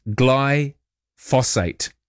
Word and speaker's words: glyphosate: And you are adding an extra syllable. It's glyphosate.